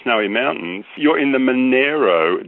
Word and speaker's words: Monaro: Go to the Snowy Mountains and say you're in the Monaro country, and you'll be known immediately as not a local.